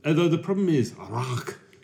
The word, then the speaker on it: Rauch: I'm like, oh, it's not pronounced Rausch. He's like, Rauch. Rauch.